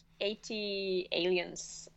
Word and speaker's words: ATLiens: I like 80 Aliens. I think that's a good one. Is that how you say it even? Yeah, by Outkast.